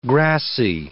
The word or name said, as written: grassy